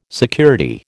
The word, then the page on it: security: American English